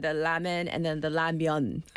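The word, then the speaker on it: Ramyeon (라면): Anybody in the U.S. that's listening, what do you say? What's your... – They say ramen. – Ramen. – Ramen. – Still. – Right, still. – Okay, got it. – Because that's how it's spelled. – But I don't say that anymore. – What do you say? – Ramyeon. – Of course, the myeon, the noodles.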